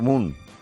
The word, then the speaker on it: MUN: ¿De qué es este Harvard World Moon? Moon, porque se escribe M-U-N, ¿no, Mónica? – Ah, sí.